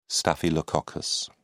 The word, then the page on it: staphylococcus: British English